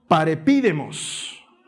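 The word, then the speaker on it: parepidemos (παρεπίδημος): Literalmente es una palabra griega que se pronuncia parepidemos y que quiere decir peregrinos o exiliados, literalmente gente que no pertenece a ese lugar.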